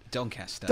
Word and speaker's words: Doncaster: What happened with, is it Doncaster? How do you pronounce it? – Doncaster. – Doncaster.